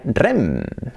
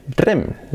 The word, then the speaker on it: R.E.M.: Bueno, aquí en este caso tiene truco porque se dice letra por letra. Se dice R-E-M. En inglés, R-E-M. R-E-M.